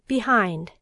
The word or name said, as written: behind